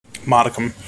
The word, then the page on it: modicum: American English